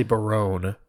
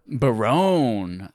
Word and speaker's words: Barone: The gentleman is Eric Baroni. Baroni rhymes with baloney, or maybe it's Baron with an E on the end. – I would say Barone. – Barone.